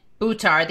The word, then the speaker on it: Buttar: And his name again is Shahid Buttar, and it's pronounced like this, guys. Shahid, so the accent's on the and Buttar, the accent's on the ooh.